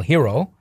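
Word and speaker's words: Heroux: Paul Hero, we've all were pronouncing his name wrong for maybe 6 weeks, and now we learn it's Paul Hero, the next sheriff of Bristol County.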